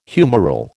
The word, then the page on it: humoral: American English